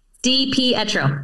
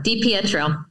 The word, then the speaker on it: Di Pietro: DiPietro. DiPietro.